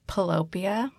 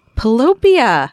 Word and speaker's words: Pillowpia: It's actually pronounced Pillopia. – Pillopia. – Like utopia. Like a heaven of pillows, a place, your happy place.